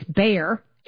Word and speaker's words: Bayer: Bayer, we pronounce it here in the States, Bayer.